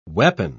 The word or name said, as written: weapon